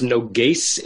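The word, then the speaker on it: noges (נֹגֵשׂ): Nogash haya mimuna al shotrei Yisrael. He was a. – So the word is pronounced noges. It's a sin, not a shin.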